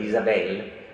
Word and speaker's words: Isabelle: È una bambina che si chiama Isabelle, fa sapere subito che l'accento è sulla seconda sillaba, Isabelle, in modo che qualcosa ci dica che, benché tutto si svolga in Italia, c'è un altrove alle spalle di questa Isabelle.